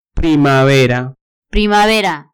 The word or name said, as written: primavera